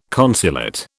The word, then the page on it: consulate: British English